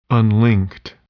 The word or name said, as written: unlinked